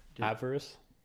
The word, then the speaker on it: avarice: How do I say this word? Avarice? – Avarice. Yeah.